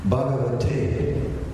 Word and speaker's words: Bhagavate: Bhagavate, the word is broken up. It is broken up in three syllables. It's like Ba, Ga, and Va.